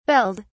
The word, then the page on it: belled: British English